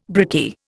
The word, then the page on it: brickie: Indian